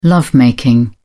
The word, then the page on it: love making: British English